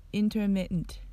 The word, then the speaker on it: intermittent: となります発音の注意点は intermittent ですこの単語を分解すると in ter mit tent に